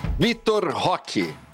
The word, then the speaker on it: Vitor Roque: Vitor Roque.